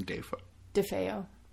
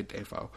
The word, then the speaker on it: DeFeo: How do you say the last name? – DeFeo. – I was going to say Defoe.